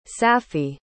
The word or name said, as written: safi